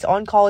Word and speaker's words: en caul: And if you don't know what on call means, on call is spelled E-N-C-A-U-L. It's a French word.